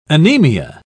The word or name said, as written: anemia